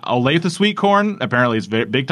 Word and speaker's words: Olathe: O-L-A-T-H-E. How would you pronounce that? – Olathe? – I thought it was Olaf. – Olaf? – But it was Olathe. – Olathe. That was my eighth guess.